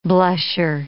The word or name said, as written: blusher